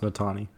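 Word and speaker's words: Netane: His name is pronounced Natani. It's Natani Muti. – Natani Muti. I always thought it was Natani, but then I always had people yell at me that it was Natane.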